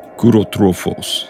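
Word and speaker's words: Kourotrophos: Kurotrophos. I like that, Max. That was a good pronunciation.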